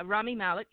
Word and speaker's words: Rami Malek: And though Rami Malek in Bohemian Rhapsody did how do you pronounce his name? – Yeah, Rami Malek. You said it right.